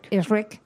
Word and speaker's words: Ehrlich: Pues mira, Max Ehrlich, bueno, no sé si lo he dicho bien. – Max Ehrlich. – Ehrlich. Como el monstruito verde, exacto.